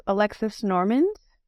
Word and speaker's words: Alexis Normand: My name is Alexis Normand. I spell it A-L-E-X-I-S-N-O-R-M-A-N-D.